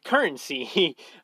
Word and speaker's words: Currensea: And basically what it is you can upload your work on there, and people can tip you in currency, which is, it's spelt weird. It's spelt Currensea. It's currency.